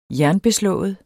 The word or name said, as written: jernbeslået